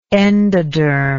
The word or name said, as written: endodermic